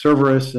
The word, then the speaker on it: Cerveris: It's Cerveris, and it's spelled C-E-R-V-E-R-I-S.